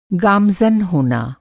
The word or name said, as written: गामज़न